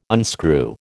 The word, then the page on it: unscrew: American English